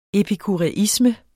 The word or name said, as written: epikuræisme